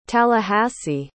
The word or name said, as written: tallahassee